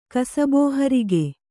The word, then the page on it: ಕಸಬೋಹರಿಗೆ: Kannada